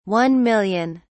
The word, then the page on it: 1000000: British English